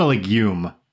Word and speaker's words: Legum: He's not a legume. He's Legum.